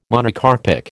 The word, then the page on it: Monocarpic: American English